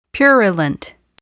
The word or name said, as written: purulent